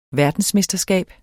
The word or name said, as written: verdensmesterskab